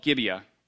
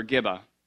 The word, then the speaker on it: Gibeah: And a place called Gibeah, or Giba, or Geba, depending on how you say it.